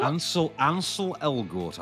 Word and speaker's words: Elgort: Ansel Elgort, I believe, is the pronunciation, yes.